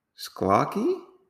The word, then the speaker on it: schlocky: So, some of the reviews for this film are delightfully sklocky. I never heard of that word before. Sklocky. I think that's how you say it. S C H L O C K Y.